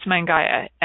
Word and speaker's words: Mangaia: I'm going to pronounce it manjia Gaia, Mangaya, I think it's Mangaya, M A N G A I A, Mangaya, a tiny island in the South Pacific, is home to some of the earliest and most advanced sexual education.